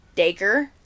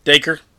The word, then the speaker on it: Dacre: Uh, how you say his name? Dacry. Dacry Montgomery. – Dacre. – Dacre. Dacre Montgomery. I like Dacre.